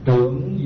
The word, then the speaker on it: trưởng: Trưởng dưỡng ư bạch pháp, nhi thành ư thế tôn. Trưởng dưỡng chữ trường mà đọc là trưởng, trưởng dưỡng ư bạch pháp, nhi thành ư thế tôn.